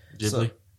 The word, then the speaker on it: Ghibli: What are the Studio Ghibli? Is that how you say it? – Ghibli.